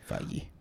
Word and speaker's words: Feige: They're just like at the very end of the panel, they just do a, like Kevin Feige or Figure, however you say his name, is just like Feige.